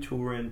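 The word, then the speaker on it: Túrin: And names like Hurin, Turin, Tuna should be pronounced ooh. So tuna.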